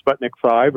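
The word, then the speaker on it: Sputnik V: Sputnik Five or V as it's pronounced,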